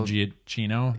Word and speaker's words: Giacchino: How do you say his last name? – Michael Giacchino? – Yeah, yeah.